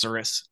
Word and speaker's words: cirrus: But then there's one that's, I believe it's pronounced cirrus. It's like C-I-R-R-U-S, Homo genetis cirrus clouds, which are the long ones, which are only found behind jets.